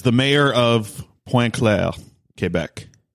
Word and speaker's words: Quebec: You even said Quebec. – Yeah, well, that's how you say it.